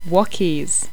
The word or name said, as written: wockies